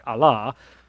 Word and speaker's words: Allah: And then Allah, we usually hear it Allah, A-L-L-A-H. You can spell it A-L apostrophe L-A-H, Allah. That's how they would pronounce it.